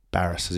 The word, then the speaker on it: Barras: Barras, as in barrister. – Oh, I've always thought embarrassed.